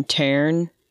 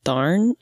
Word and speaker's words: Tairn: but the Gaelic pronunciation of this one is Tharn. Or, you know, we're going to give it a hard T. I'm gonna go with Tharn.